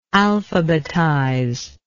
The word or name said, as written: alphabetizer